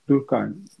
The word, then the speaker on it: Durkheim: E, mesela Freud'un Yas ve Melankoli makalesindeki eksikliği Durkheim e, öyle telaffuz ediliyor değil mi Durkheim? Durkheim. Ben öyle yani ediyorum. Durkheim yani. – diye telaffuz ediyoruz.